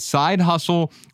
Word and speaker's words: Side Husl: It's S I D E H U S L. And uh, Side Husl, uh, the title of it is 450 ways to make money on the side. Side Husl researches, reviews, and rates more than 450 online platforms that allow you to make money on the side.